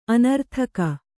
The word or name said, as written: ಅನರ್ಥಕ